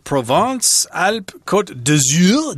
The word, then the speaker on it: Provence-Alpes-Côte d'Azur: We're actually going to someplace called Provence-Alpes-Côte d'Azur. Did I say that right? Pretty good. Yeah, that's good.